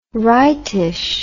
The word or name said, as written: rightish